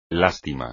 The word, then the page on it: lástima: Spanish